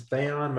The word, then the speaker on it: Thaon: My name is James Theon. My last name is spelled T-H-A-O-N.